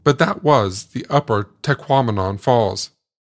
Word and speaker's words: Tahquamenon: But, as I learned from a sign within the park, one easy way to get it right is to remember that it rhymes with phenomenon, as in Tahquamenon.